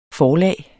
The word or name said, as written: forlag